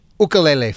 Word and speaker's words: ukulele: Tell us about the New England Ukulele Festival. Am I saying it correctly or is it ukulele? – You are saying it correctly.